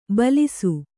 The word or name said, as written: ಭುಲ್ಲೈಸು